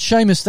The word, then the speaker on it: Seamus: Now, it's Seamus. That's how you pronounce it, isn't it? S-E-A-M-U-S.